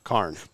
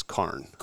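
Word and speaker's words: Cairjn: So I was actually talking to somebody from Australia, and so the word Karn is Gaelic in nature, and so when she pronounced it Karn. And so that kind of stuck as like the pronunciation of the word.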